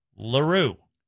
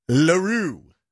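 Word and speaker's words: L'Heureux: That is capital L apostrophe, capital H-E-U-R-E-U-X. Pronounced Leroux. – Leroux.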